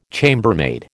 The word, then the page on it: chambermaid: American English